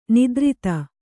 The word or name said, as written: ನಿದ್ರಿತ